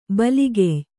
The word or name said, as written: ಬಲಗೆಯ್